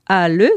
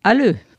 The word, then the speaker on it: ALE: Elle est aussi et surtout la présidente de l'association ALE, c'est-à-dire Association le latin dans les littératures européennes. Donc on doit prononcer le E, ALE. – ALE. – ALE ou ALE, c'est comme on veut.